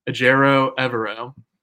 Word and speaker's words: Evero: I looked up his pronunciation. Ejiro Evero. Evero is not too bad, but yeah, so Evero is really good.